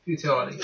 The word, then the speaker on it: futility: fertility or fertility, fertility, fertility. Oh, how do you say that? Futility.